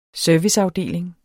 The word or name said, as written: serviceafdeling